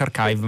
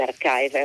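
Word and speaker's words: arXiv: Magari ci spieghi perché si scrive archive, si pronuncia archive ma è scritto con la X, con una X grande. – Sì, in, in, realtà è una X quando si scrive sul computer, però è una key greca, ecco. per cui eh, l'idea è di leggerla come una key e quindi si legge archive, è un, è un modo eh, più breve per scrivere key.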